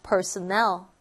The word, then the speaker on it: personnel: personal 할때 악센트는 여기 있고요. personnel이라고 하면 강세가 뒤로가 있습니다. 그러면 여기서는 직원들이라고 하는 뜻이죠. personnel 이라고 발음합니다.